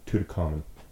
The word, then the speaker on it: Tutankhamun: Tutankhamun. – What? It's not a long A. I thought it was Tutankhamun.